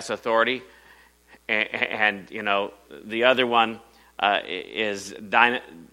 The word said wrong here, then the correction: Dunamis: How do you say that? Dunamis.